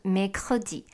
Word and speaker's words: mercredi: 者来说，mercredi 星期三跟星期五 vendredi 可能会比较难一点点，因为星期三有可跟和的音要放在一起，所以就念成 mercredi。